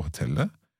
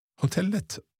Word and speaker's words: hotellet: Hotellet eller hotelle? – Hotellet. – Hotellet, so you always pronounce that T on the end. – Yeah. – Yeah, okay. That's a Norwegian thing that's coming to my mind there. Okay, so because I don't think we need to pronounce the T in the end of a Norwegian word.